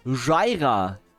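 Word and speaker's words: Jira: Jaira. Genauso geschrieben, wie es ausgesprochen wird.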